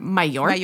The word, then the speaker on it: Mallorca: And then the second thing was that Val, shout out Val, energy healer Val, who's in Majorca. – She's always how do you say that? – Mallorca. – Mallorca. Oh yeah, yeah. That's how you say it. But it's spelled Majorca.